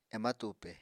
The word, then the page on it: ema tupe: Apatani